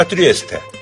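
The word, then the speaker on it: Trieste: 아, 트리에스테.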